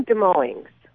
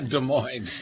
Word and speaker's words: Des Moines: Am I pronouncing that right? Dueling? – Oh, I'm from Des Moines. – Oh, Des Moines.